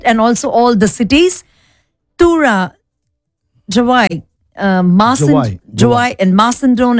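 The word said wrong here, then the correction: Mawsynram: and I'm pretty sure we are not pronouncing it right. – You're not pronouncing it right. – Oh, do you think so? – Because I went to YouTube and said how to pronounce. – Oh. – It's Masandrum.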